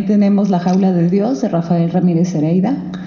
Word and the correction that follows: Heredia: Heredia, lo dije mal, y La Vida Mental, de Henry Wallum.